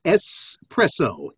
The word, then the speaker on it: espresso: And just because this is a particular bugaboo of mine, it's espresso. And I know you're right. It's not X, it's S.